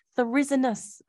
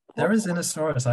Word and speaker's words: Therizinosaurus: Therizinus. – Therizinosaurus.